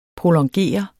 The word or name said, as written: prolongere